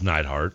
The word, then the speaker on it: Neidhart: What if you did like a Jim the Anvil Neidhart thing? And like, it's Neidhart.